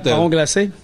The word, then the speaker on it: marron glacé: I have bought the marron glace. Is that what? How do you say that? – Marron glacé.